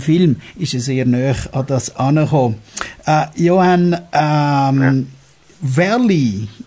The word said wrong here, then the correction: Verle: your partner, how do you pronounce that correct? – Verle, okay. – Yeah. – Verle Batens, okay. – Yeah, perfect.